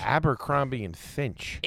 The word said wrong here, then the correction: Fitch: It's a T, not an N, dude. – Oh, there's no N? – Fitch.